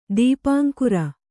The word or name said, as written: ದೀಪಾಂಕುರ